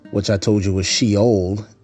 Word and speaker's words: Sheol: The word in Hebrew for that is Sheol, Sheol. Now, in English, we spell it as S-H-E-O-L, Sheol.